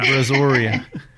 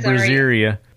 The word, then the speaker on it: Brazoria: Brazoria. I kept calling Brazoria. It Brazeria.